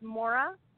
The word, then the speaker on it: Moire: Okay, it's Moire, and mm-hmm.